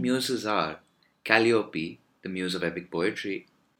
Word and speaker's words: Calliope: And It's pronounced Calliope.